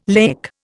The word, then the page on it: laic: Indian